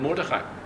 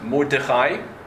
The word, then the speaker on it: Mordechai: But actually, there is a minhag that exists in a number of kehillas that his name is not to be pronounced Mordechai with a shiva, but Mordechai with a kamatz.